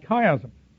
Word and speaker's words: chiasm: Now here we have something in the verse that's called chiasm. And you might say, chi what? Chiasm. The Greek letter chi, or as it gets repronounced in this word, chi, is shaped like an X.